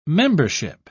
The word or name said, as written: membership